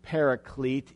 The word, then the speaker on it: paraclete: That word, you might pronounce it paraclete in English, but the word is describing a helper or a advocate or a counselor or even a comforter.